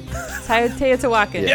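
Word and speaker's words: Teotihuacan: Tiotihuacan.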